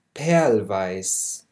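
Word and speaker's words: Perlweiß: نعيد بس لفظة هون كمان مرة تانية، بيج، غراو، تركيز، أوليفن جرون، هيمل بلاو، بيبي بلاو، بينك، بيرل فايس. بيرل فايس الاير هون تلفظ لفظ خفيف.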